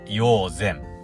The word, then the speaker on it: 医王山: ちょっと中国地方とかに多いんですけど、山と書いて線とか、善とか読んだりします。ね。硫黄山と書いて硫黄善に、えー、登ってきました。